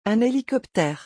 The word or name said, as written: un hélicoptère